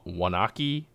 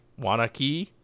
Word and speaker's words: Waunakee: from Wild Mind Artisan Ales from Wa- Waunakee, Waunakee, Wisconsin.